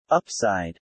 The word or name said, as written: upside